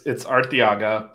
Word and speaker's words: Arteaga: Arteaga? Am I saying it right? – So it's Arteaga, but I'm saying it wrong.